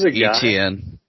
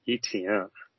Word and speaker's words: Etienne: His name is Etienne. – Oh, Etienne. It's like E.T. go home.